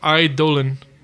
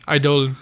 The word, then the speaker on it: Eidolon: And apparently the summons from Final Fantasy, you know, 10 and up or whatever, they're actually pronounced Eidolon. – Eidolon? – Eidolon. It's a real word.